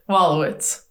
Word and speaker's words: Wolowicz: So, Wolowicz.